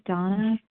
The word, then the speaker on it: Dana: This is Donna B. My name is spelled D-A-N-A, and I'm calling from Denver, Colorado.